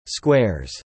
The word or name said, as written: squares